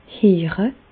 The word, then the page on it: heere: Bas-Rhin; Reichshoffen